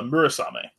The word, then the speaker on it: Murasame: mysterious um, Marasame Castle. Did I say that right? Uh, Murasame.